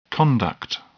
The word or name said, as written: conduct